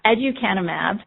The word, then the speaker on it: aducanumab: Aducanumab is an investigational human monoclonal antibody studied for the treatment of Alzheimer's disease.